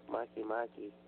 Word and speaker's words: Makemake: Some of the smaller planets, such as Pluto and Makemake, I talk about that in my book, Makemake. That's how I pronounce it.